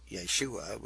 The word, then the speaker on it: Yeshua: or Yeshua would be more correctly pronounced.